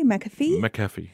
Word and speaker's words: McAfee: Is it McAfee? – McAfee, yeah.